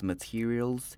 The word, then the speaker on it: material: 再来是 material material，重音在第二音节， te 的正确发音是 t 不是 tail 不是 te，念成 material material。